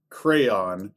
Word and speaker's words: crayon: C-R-A-Y-O-N. – Oh, good. Crayon. See, I take the letters that are there and I pronounce them. – Wait, that's, say that again? – Crayon. – Okay. – See, there's a whole Y in there and there's a whole O in there. Because I know there's people that pronounce it crayon.